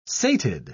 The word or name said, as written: sated